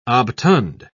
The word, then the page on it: obtund: British English